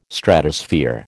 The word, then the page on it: Stratosphere: American English